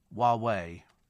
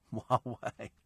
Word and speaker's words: Huawei: Huawei, says Raj, is pronounced Huawei. Huawei.